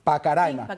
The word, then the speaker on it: Pacaraima: ¿Se pronuncia así? Pacaraima.